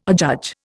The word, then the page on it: adjudge: Indian